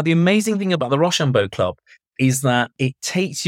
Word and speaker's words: Rochambeau: It's called the Rochambeau Club. Rochambeau as in the sort of French way you'd spell it. Rochambeau.